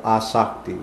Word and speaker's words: Asakti: Ruchi, because the next stage is called Asakti with a long A in the beginning.